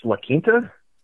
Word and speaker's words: La Quinta: And I believe, isn't that pronounced La Quinta? – Yes, like, yes, it is.